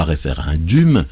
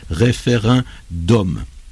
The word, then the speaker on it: référendum: Ça se termine par DUM, mais ça se prononce non pas référendum, non pas référendum, mais en français on dit référendum eh bien, c'est qu'il a été emprunté tel quel à la langue latine,